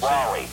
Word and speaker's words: Wolle: Is that W-O-L-L-E? Is that how you pronounce that? – What? – Is that Wall or Wally? Is that the Wally. – Same? Wally. Okay, we weren't sure. – W-O-L-L-E, Wally. And his father, Hartley C. Wally, fell out of an airplane and died.